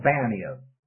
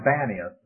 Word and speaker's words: Banias: Panias or Banias. Someone said that was just a matter of pronunciation, that the Palestinians would always use a P for a B. If you send them to Bombay, they might end up in Pompeii.